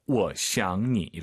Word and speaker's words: 我想你: 삼성이 세 개네요. 이럴 땐 주어가 반삼성입니다. 我想你. 이렇게 발음하시면 좋을 것 같아요.